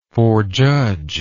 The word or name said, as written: forjudgment